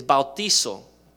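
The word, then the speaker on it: bautizo (βαπτίζω): And so the original Greek word where we get the word baptism from or baptize is bautizo. And the reason I say with a Spanish pronunciation is that's how you say it in Greek.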